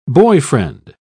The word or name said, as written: boyfriend